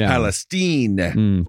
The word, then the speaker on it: Palestine: East Palestine. – Palestine, is that how they say it? – That's how they say it.